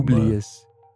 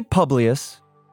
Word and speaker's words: Publius: Publius. It's Latin. Some people say Publius, because that's kind of how it's spelled, P-U-B-L-I-U-S. – Sounds like it's a butchered version comparatively.